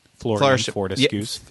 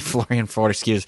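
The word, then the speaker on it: Florean Fortescue: It's the flourishing Florian Fortescue. – Yeah, I can never say it right. Florian Fortescue.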